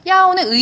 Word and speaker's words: 要: 여기 조동사는 要 이거는 주의하실 점이 요잖아요. 우리나라 그 도금으로 요기 때문에 자꾸 요라고 자꾸 발음을 하시는데 이거는 야고요. 뭐뭐 하려고 하다. 이거는 이제 거의 샹이랑 비슷한데 샹은 이제 바램을 나타낸다면 야오는 의지를 나타내요.